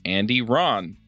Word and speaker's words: Andy Rahn: And we have a special guest this week, and that's Andy Rahn.